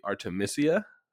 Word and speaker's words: Artemisia: We mispronounced the name of Artemisia. It's actually Artemisia, because the name derives from Artemis.